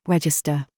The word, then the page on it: DRESS: British English